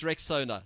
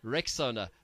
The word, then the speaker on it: Rexona: So let me just remind you, guys, I'm sure you've seen that Shield is now Rexona, but it appears some people don't know how to pronounce this new name. A friend even called it Rexona. But guys, it's Rexona, like Rexona. Rexona.